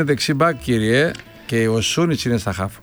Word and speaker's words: Σούνιτς: Σούνιτς, καλά το πάω. – Σούνιτς, μάλιστα, ναι. Χωρίς γάμα. – Χωρίς γάμα.